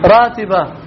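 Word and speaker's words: Ratibah: Ratiba. Ratiba R A long A T I B A H.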